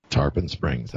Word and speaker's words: Tarpon Springs: He's based in, how do I pronounce this? Tarpon Springs, Florida? – Tarpon Springs. – Tarpon, that's correct.